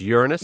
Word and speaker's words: Uranus: I'm talking about the way you pronounce it. It's Uranus.